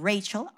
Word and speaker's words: Rachel: I know my name, Rachel, R-A-C-H-E-L. The Americans pronounce it Rachel, right? But my mom, whenever she is mad at me, she calls me Raquel, okay?